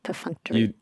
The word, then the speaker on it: perfunctory: How do you say that? Perfunctory. – You nailed it. Perfunctory.